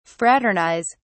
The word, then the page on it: fraternise: British English